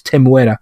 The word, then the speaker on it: Temuera: I've been saying his name wrong. It's Temuera. I've been missing Temuera Morrison now that the Book of Boba Fett's over.